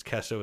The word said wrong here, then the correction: Kiso: is how you pronounce it, is a big fight fan. – Jared Kiso.